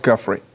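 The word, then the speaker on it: Giuffre: How do you say her last name? – Roberts Guffrey. – Okay, Guffrey. Virginia Roberts Guffrey.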